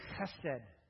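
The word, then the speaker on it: chesed (חֶסֶד): It's the word chesed. That hurts. Ouch. Chesed with a kind of sound at the beginning.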